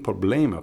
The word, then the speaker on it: problem: Very good. Problem. Los labios están cerrados cuando digo la M.